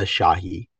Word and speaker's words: Shahi: Hey everyone, my name is Arya Shahi. So that's how you say it. Arya, like the Italian Arya, and Shahi, like Shahi, I guess.